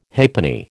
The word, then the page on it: halfpenny: American English